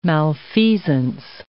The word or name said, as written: malfeasance